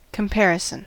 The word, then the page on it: comparison: American English